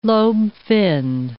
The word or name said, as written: lobe-finned